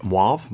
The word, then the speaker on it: mauve: Mauve? Mauve. – Mauve. – Mauve. Yeah, there's no W in there. – Yeah. – I mean, there's no W sound, at least.